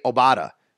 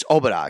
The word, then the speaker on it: Obada: I really like, and I think the most underrated signing here and most intriguing might be F.A. Obada, or it's Obada, excuse me, from the, I think that's how you say it, from the Carolina Panthers.